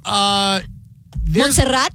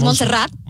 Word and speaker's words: Montserrat: Montserrat in Spanish is Montserrat. So, like, you know what I mean? So it sounds like rat. – Okay, because I get Montserrat,